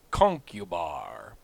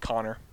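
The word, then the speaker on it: Conchobar: Years and years and years ago, in college, we're reading some old Irish legend, and there's a king whose name looks like it's pronounced concubar, but it's like with a CH at the beginning. So I guess it would be like chonkubar, but we all thought it was concubar. It's pronounced Connor.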